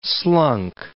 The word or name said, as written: slunk